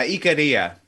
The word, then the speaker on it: Icaria: how do you say it? Icaria? – Yeah, Icaria. – Icaria, right. We got to say it with the Greek.